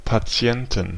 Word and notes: Patienten: /paˈtsi̯ɛntən; paˈtsi̯ɛntn/

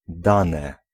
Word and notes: dane: /ˈdanɛ/

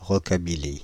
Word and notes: rockabilly: [ʁɔ.ka.bi.li]; /rɔ.ka.bi.li/

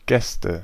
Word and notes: Gäste: IPA: /ˈɡɛstə/; German

